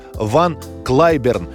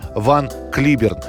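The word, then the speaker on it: Van Cliburn: Победителем среди пианистов на первом международном конкурсе имени Чайковского в Москве становится американец Ван Клайберн, или наше произношение этого имени Ван Клиберн.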